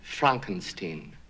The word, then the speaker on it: Frankenstein: My name, it's pronounced Frankenstein.